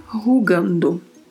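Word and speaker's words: jugando: E a particularidade de pronúncia que temos aí é a primeira letra da palavra a primeira letra, que é a letra J. J. Pronuncia-se assim, com este som, que nem é um R como o R do português, não tem um som vibrato, mas gera este som. Rugar, rugando. Em, que é uma preposição, e ele, que é o artigo masculino no singular.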